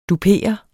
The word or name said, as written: dupere